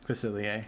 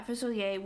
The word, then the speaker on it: Facilier: Yeah, you put that down, and I had Dr. Facilier. Is that how you say his name? – Facilier. – Yeah, Facilier, which was the one that I could shift into, so he was the one with the 3 lore.